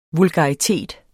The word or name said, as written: vulgaritet